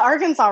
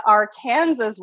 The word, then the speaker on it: Arkansas: For me, because I grew up in Oklahoma, I call it the Arkansas River. In Wichita, though, they pronounce it the Arkansas River.